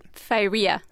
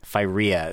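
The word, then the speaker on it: FIRREA: FIREA. – FIREA.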